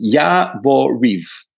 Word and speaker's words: Yavoriv: But what happened was that the Americans started assembling forces at a base called Yaroviv. Yavoriv. I always mix up the pronunciation. Yavoriv.